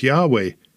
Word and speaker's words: Yahweh: spelled out in the Tetragrammaton as YHWH or YHVH, and they'll pronounce that as Yahweh, and they consider that proper and acceptable.